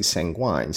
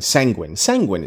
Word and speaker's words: sanguine: I always say sanguine. Sanguine is the pronunciation.